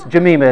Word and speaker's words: Jemima: Jemima, which is actually the way you pronounce it, I know you guys are fixated on food, but it's not. It's Jemima is the way that it's pronounced in the Bible.